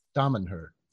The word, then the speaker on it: Damanhur: Damanhur.